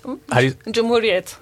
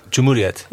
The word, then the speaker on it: Cumhuriyet: Cumhuriyet. – Cumhuriyet.